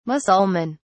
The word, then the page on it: mussulman: British English